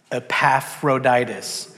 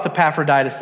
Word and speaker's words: Epaphroditus: We're going to come across the name that's pronounced Epaphroditus. On the count of three, I want everybody to say Epaphroditus. One, two, three. – Epaphroditus. – Beat the nine, I think. Beat the nine. Sure. When you come across Epaphroditus, that's how you say it.